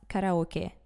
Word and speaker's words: karaoke: And yes, it is pronounced karaoke, not karaoke.